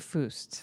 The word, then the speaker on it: Fuest: Did I pronounce that right? Robert Foost. Foost, Okay.